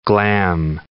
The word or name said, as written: glam